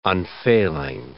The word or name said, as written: unfailing